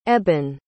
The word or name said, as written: eben